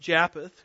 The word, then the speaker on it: Japheth: They were the sons of Jephthah. I think better pronounced Japheth, excuse me. There's another guy named Jephthah. I don't want to confuse those two.